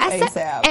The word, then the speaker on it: ASAP: I don't know if I'm saying his name right, but is it ASAP? – Girl, it's ASAP.